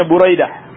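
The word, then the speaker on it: Buraidah (بريدة): Yang keempat Buraidah. Bidommi awwalihi wa fathi tsanihi. Ba'nya di dommah dan ra'nya di fathah. Jadi bacanya Buraidah.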